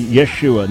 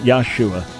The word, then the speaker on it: Yeshua: But it is actually Yeshua, not Yahshua. That's a non-existent name.